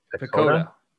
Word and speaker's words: PECOTA: So I mispronounced this, and now I'm like in my head about what this projections are. What is it? – Pecota. Yep.